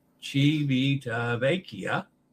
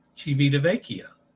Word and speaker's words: Civitavecchia: Civitavecchia. Civitavecchia. Mind your E pronunciation in time. Vecchia. Civitavecchia. I thought I did that right, Dennis. Anyway, Civitavecchia is the way I say it now.